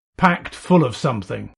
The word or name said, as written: packed full of